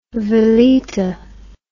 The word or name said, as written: valeta